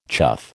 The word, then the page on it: chuff: American English